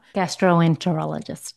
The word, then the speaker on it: gastroenterologist: And I always mispronounce one of my specialists. Is it gastroenterologist? – Gastroenterologist. – That's the one.